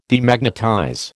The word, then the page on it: Demagnetize: American English